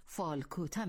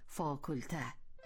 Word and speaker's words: فاکولته: این را هم یادآوری کنیم که گاه مردم نه تنها کلمه خارجی فاکولته را به زبان می آورند، بلکه آن را اشتباه هم تلفظ می کنند، یعنی فالکوته می گویند. ما اگر بخواهیم این کلمه را به کار بریم، باید آن را به صورت درستش تلفظ کنیم، یعنی فاکولته.